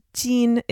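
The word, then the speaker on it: Jin (金): In Chinese, Jin is how Kim is pronounced.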